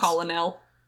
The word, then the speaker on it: colonel: I don't know why, but I hate the way that it's spelled versus the way it's pronounced. – Colonel.